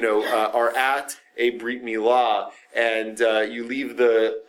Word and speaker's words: brit milah: Um, brit milah, sometimes known as a bris, because uh, um, the Hebrew word brit, which means covenant, um, in uh, uh, the Ashkenazi pronunciation of it, right from European Jews, is a bris, a bris milah instead of a brit milah. Um, the Sephardi pronunciation of the t, the Tuv in, uh, in, in Ashkenazi circles was usually a soft s.